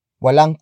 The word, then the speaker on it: walang: Walang kurtina ang bintana.